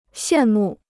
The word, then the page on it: 羡慕: xiàn mù